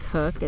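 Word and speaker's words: Koch: I think you pronounce it Cook. It's K-O-C-H.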